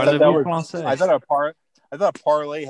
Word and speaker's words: Parler: It should be pronounced parlay, you stupid American.